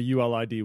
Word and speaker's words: ULID: So I was not clear on what a ULID was. A ULID, if you if you pronounce it like so.